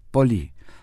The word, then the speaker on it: πολύ: ευχαριστώ, con el acento en la O, la última O, y πολύ, acento en la I. Son muy importantes la acentuación, dado que si nosotros ponemos mal el πολύ, la acentuación, decimos πολύ, πολύ significa ciudad.